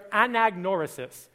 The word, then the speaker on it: anagnorisis: In ancient Greek drama, this moment, what we have just experienced, is called the anagnorisis. All right, here's some trivia for your Easter lunches that you can break out for anybody that wasn't at PBC. Can we say that together? Anagnorisis.